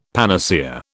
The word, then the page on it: panacea: British English